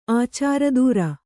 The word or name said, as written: ಆಚಾರದೂರ